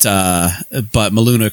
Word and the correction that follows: Maulana: Karenga is, or Maulana Karenga. I'm sorry. I should pronounce that better, even if he's an asshole. I should still pronounce African names properly.